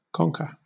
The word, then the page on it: conker: British English